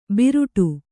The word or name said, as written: ಬಿರುಟು